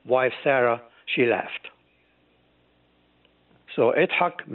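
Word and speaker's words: Yitzhak: The correct pronunciation is Itzhak. You remember the great the late president of Israel, his name was Itzhak Rabin. It was not Isaac Rabin, it was Itzhak Rabin.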